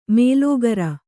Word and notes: ಮೇಲೋಗರ: mēlōgara

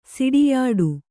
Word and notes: ಸಿಡಿಯಾಡು: Kannada

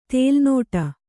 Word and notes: ತೇಲುನೋಟ: tēlu nōṭa; Kannada

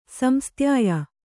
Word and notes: ಸಂಸ್ತ್ಯಾಯ: samstyāya

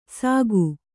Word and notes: ಸಾಕು: sāku

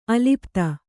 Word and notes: ಅಲಿಪ್ತ: alipta

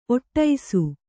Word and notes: ಒಟ್ಟೈಸು: Kannada

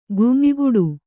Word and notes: ಗುಂಯಿಗುಟ್ಟು: gum`yiguṭṭu; kannada

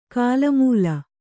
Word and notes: ಗಾಲ್ಮೇಲು: gālmēlu

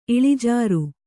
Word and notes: ಇಳಿಜಾರು: kannada

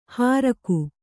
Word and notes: ಹಾರಕು: hāraku

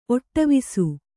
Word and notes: ಒಟ್ಟವಿಸು: Kannada

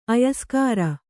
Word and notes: ಆಯಸ್ಕರ: āyaskara

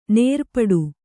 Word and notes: ನೇರ್ಪಡೆ: nērpaḍe

